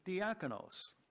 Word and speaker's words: diakonos: That word, servant, is diakonos. And I'll spell that for you. It's D-I-A-K-O-N-O-S. D-I-A-K-O-N-O-S.